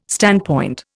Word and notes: standpoint: Indian

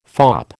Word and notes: fop: American English

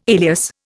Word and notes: alias: Indian

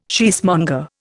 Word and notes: cheesemonger: Indian

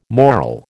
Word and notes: moral: American English

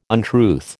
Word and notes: untruth: American English

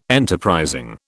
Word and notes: enterprising: British English

